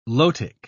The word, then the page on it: lotic: British English